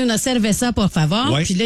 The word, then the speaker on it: cerveza: Et là, j'ai googlé, j'ai demandé à ChatGPT, tout ça, puis ça a l'air que le V en espagnol, il se prononce presque comme un B. Là, on va l'essayer. Votre bouche est fermée au début. Fait que ça serait una cerveza.